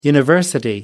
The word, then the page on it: university: American English